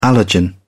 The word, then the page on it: allergen: British English